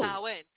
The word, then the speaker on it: Samhain: It is sow as in cow and in. – Oh. – Samhain.